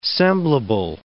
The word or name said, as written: semblable